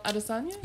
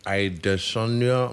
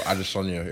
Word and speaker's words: Adesanya: Israel Adesanya? – Yeah. Oh, you said it right. Wow. – Yay! – No, most people never say it properly. It's like, Adesanya. I'm like, no. But Adesanya, yep.